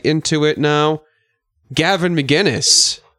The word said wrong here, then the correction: McInnes: It's Gavin McInnes.